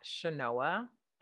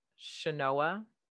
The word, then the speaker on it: Chenoa: So I'm on Instagram at Shanoa underscore Daniels. My first name is spelled C-H-E-N-O-A. Underscore Daniels with an S, Daniels, and on Facebook at Shanoa Murray Daniels. So Shanoa M-U-R-R-A-Y Daniels.